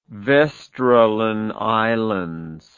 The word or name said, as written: vesteralen islands